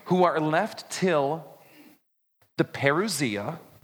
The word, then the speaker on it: parousia: It's pronounced like this, parousia. Now, don't listen to me say it casually, because I always say parousia. I say it wrong, all right? It's parousia.